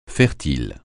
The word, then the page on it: fertile: francais